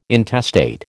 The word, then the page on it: intestate: American English